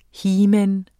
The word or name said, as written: heman